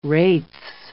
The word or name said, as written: wraiths